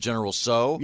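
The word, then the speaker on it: General Tso: No, well, you don't have to hit that. – You just pronounce it General So. – Yeah. – Oh, I thought you were supposed to hit the T. – No. Not okay. necessary. – I'll say sorry. – No, the T is silent like in pterodactyl.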